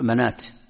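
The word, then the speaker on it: مناة: وهكذا تجد الزكاة نفس الشيء، تجد مثلا الربا مشكات، مشكات هكذا، وهكذا تجدها يعني مثل هذه الأشياء، مثل منات، ومنات الثالثة مكتوبة منوة، مكتوب منوة كيف ننطقها؟ نقول منات بالألف، هذه تسمى إبدال الألف واوا.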